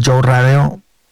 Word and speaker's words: Joe Radeo: Pues en mis redes sociales, tal cual como se escucha mi nombre, Joe Radeo, J-O-E, se escribe J-O-E, Radeo, R-A-D-E-O.